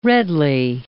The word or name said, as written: redly